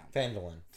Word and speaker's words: Phandalin: Phandalin.